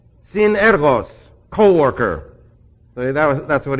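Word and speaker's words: sin (σύν): The sin there, by the way, commonly pronounced soon, it's sin.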